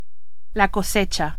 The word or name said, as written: cosecha